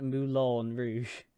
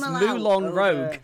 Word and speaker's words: Moulin Rouge: chat is saying that she pronounces it Moulin Rouge. Although, actually, my brain goes to pronounce that last word as rogue because of D&D. – Yeah. – So really, my brain's immediate thought is Moulin Rogue.